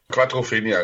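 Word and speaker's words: Quadrophenia: Quattrophenia. – Quattrophenia, richtig.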